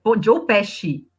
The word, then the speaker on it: Joe Pesci: Joe Pesci.